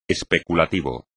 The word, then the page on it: especulativo: espagnol